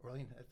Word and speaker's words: Orlean: Orlean, that's what I thought.